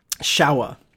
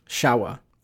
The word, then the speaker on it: Showa: Showa. – What? – Showa. Like, how, go take a shower. – How do you spell that? – S-H-O-W-A.